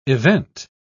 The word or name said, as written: event